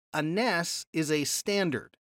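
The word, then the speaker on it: Ness (נס): So starting at number one on your handout, we have to know something about the word for banner and the Hebrew word Ness, N-E-S, we would pronounce it.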